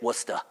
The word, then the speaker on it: Worcester: That's how you say it, Worcester.